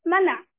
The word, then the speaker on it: 玛拿: 是没得吃的时候，神让他们出去捡玛拿。这个很有意思哈，我们都叫它玛拿。其实那两个字的发音也不发马，也不发拿。 mana 啊，它大家注意没有，都有两个口字边，它是个象声词 mana。